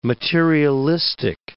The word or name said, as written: materialistic